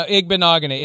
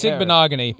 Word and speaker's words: Igbinoghene: No, Igbinoghene. It's Igbinoghene, yes.